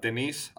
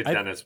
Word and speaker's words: Denis: So I'm very excited to see what Denise has to bring to the. Did I pronounce that right, by the way? I was going to say Dennis, but. – I think the S is silent, Denis.